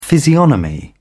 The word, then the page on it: physiognomy: British English